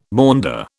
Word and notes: maunder: British English

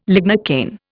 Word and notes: lignocaine: Indian

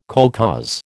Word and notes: kolkhoz: American English

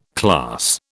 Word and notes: class: British English

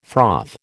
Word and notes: froth: American English

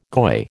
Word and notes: goy: American English

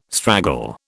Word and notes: straggle: British English

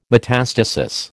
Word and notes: metastasis: American English

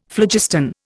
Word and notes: phlogiston: Indian

